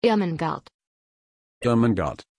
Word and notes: irmengard: German

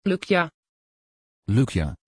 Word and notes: Lucja: Dutch

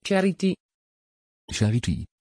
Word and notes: charity: Dutch

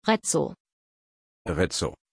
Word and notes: Rezzo: German

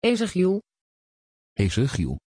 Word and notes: ezéchiel: Dutch

